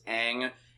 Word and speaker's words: Aang: This is why we're bemoaning all these pronunciations in the film, because it's the Avatar, it's Aang, it's Sokka, Katara.